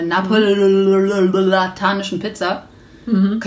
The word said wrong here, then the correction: neapolitanische: Könnt ihr das aussprechen? – Ich kann es nicht aussprechen. Neapolitanische Pizza. Oh, ich wollte Napoleon sagen.